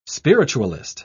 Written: spiritualist

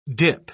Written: dip

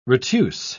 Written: retuse

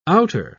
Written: outer

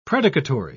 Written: predicatory

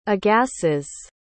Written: agassiz

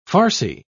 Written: farcy

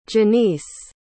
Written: Janiece

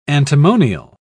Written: antimonial

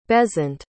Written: besant

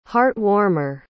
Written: heart-warmer

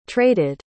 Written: traded

